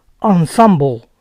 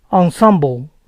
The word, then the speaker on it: ensemble: Esta palabra significa conjunto y se pronuncia ensemble, ensemble. A teacher's pet es el alumno favorito del profesor.